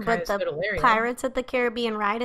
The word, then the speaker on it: Kaya Scodelario: Kaya Scodelario. And it's spelled exactly how it sounds. K-A-Y.